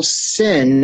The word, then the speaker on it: Sin: And this is not uncommon when Cardinal Sin, that's how you say it, Cardinal Sin of the Philippines was the Archbishop of Manila.